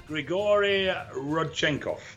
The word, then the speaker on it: Rodchenkov: It is centered around a guy, Dr. Rodchenkov? Rodchenkov? Is that how you say it? – Grigory Rodchenkov. – Rodchenkov, okay. I think I almost got it.